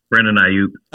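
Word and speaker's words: Aiyuk: Brandon Ayuk. – Ayuk, yeah, thank you.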